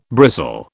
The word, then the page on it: bristle: British English